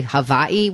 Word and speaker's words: Hawaii: You say Hawaii with a V? Is that the... – I say it with a V. Both are right. It's just a different pronunciation.